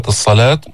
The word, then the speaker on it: الصلاة: نودي، نودي للصلاة، كمان في مد طبيعي بكلمة الصلاة، الألف الساكنة المفتوح ما قبلها، هذه الواو لا تلفظ ترسم فقط.